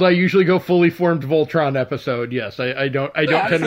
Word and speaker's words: Voltron: I said that right? It is Voltron.